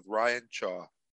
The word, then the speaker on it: Ryan Chaw: Thanks for joining the Cashflow Kings, and welcome to our new episode, College Town Investing in Hot Markets with Ryan Chaw.